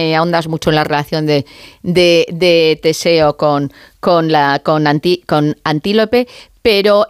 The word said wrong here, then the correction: Antíope: me gustaría, lo he dicho mal el nombre, es Antíope. – Antíope, ¿verdad? – Antíope, igual. Antíope, no, que la he llamado yo como un animal y no puede ser, Antíope.